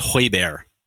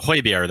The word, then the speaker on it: Højbjerg: Let me get you to pronounce that properly. It's Højbjerg. – You want me to do Højbjerg? – That's how you say Højbjerg.